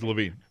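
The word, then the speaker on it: LaVine: It's Levine.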